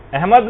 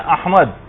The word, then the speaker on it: احمد: یعنی صحیح تلفظ ہے، الف پر زبر ہے، ہا جو ہے وہ ساکن ہے، میم کے اوپر زبر ہے، اور دال جو ہے وہ ساکن، احمد.